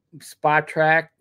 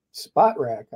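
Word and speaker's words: Spotrac: But spot track. God, I can't say that. How do you say that? Spot track. That, I think it's spot rack.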